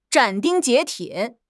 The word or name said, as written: 斩钉截铁